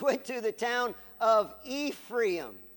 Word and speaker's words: Ephraim: Ephraim was a, and did I say that in Spanish? However it is in English. Ephraim. How do you say it in English? Ephraim? How do you say it? It's Ephraim, sorry.